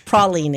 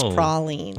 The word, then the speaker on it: praline: Praline, if you're from New Orleans. That's how you say it. Praline. – I didn't know that. – Yeah, it's pronounced praline.